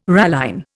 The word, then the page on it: ralline: Indian